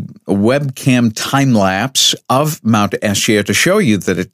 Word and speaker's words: Esja: But you'll see Mount Esya, E-S-J-A, pronounced Esya, and that's across the bay.